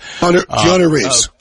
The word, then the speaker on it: Keanu Reeves: I think of Kenal Reeves, the Matrix guy. Kenal Reeves, right? Am I saying his name right? Kenal Reeves? – Keanu Reeves. – Keanu. Oh, Keanu.